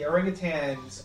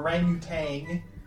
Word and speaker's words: orangutan: Okay, and officially it's orangutan, O-R-A-N-G-U-T-A-N, or it could be spelled Tang. – Which was it pronounced? – Let's see, orangutan, also spelled orangutan, orangutan, or orangutang. It can be both ways.